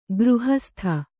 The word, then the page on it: ಗೃಹಸ್ಥೆ: gřhasthe